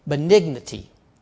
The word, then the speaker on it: benignity: Now, kindness is, let me teach you a new word, benignity. But you haven't heard that word yet today, have you, okay? Benignity, it's spelled B-E-N-I-G-N-I-T-Y, and it means, it's an adverb, meaning a kindly disposition, mild kindness.